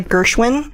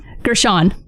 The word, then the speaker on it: Gershon: It's got Gina Gershwin. – Gershon.